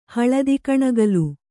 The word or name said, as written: ಹಳದಿಕಣಗಲು